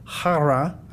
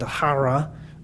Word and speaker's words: hara: It's word number H2734, hara. I'm not good at doing the huh thing, but hara is how it's pronounced, kind of.